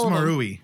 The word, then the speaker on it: Marui: How do you pronounce that? – Marui? Yeah. – I really like that one. It felt very. – Yeah, choose one of those six pronunciations we just threw out there. – I think it's Marui.